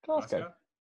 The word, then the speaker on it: Glasgow: That's not how you pronounce it, buddy. – Pronounce it for me. – Glasgow. – Glasgow. Go. Glasgow. So you just drop the W.